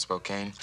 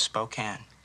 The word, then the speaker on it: Spokane: Well, let me tell you, if I'd have been from 50 miles outside of Spokane... – Spokane. It's pronounced Spokane.